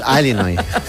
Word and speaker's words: Illinois: Es un estado de Estados Unidos que se pronuncia así. Es sí, Illinois, pero es Illinois.